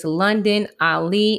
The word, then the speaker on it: London: If you have Facebook, my name is London Ali. It's spelled L-O-N-D-O-N. Last name is A-L-I.